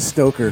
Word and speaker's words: Stoker: You said Stroker instead of Stoker.